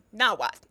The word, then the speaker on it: Nahuatl: Wow uh, yeah, the, the language is not Nahuatl, it's Nahuatl. Um, this is actually very important because the, the the emphasis on the word goes on the second to last syllable. So when you pronounce it "tul," you're adding a whole extra syllable, which fucks up your pronunciation. – Mm-hmm. – You know that's why that's why your white professor pronounces it "nahuatl" instead of "nawat."